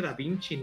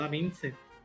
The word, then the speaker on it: Da Vinci: Lo mismo con cuando hablaban de este, de Da Vinci, se pronuncia Da Vinci, no Da Vinci.